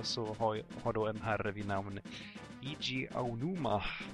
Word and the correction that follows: Eiji Aonuma: fått mer input. Och ni som kan japanska får gärna tala om för mig hur mycket jag slaktar det där uttalet. – Det uttalas Eiji Aonuma.